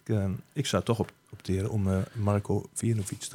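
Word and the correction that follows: Vejinović: erin te zetten. Of Marco Feinovic, zoiets was. – Feinovic, ja. Feinovic. Zo spreek je het En waarom?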